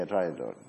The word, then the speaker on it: traidor: Did I say it right? Yeah, traidor.